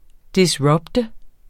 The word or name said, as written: disrupte